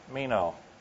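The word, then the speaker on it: meno (μένω): In the Greek, it's pronounced meno.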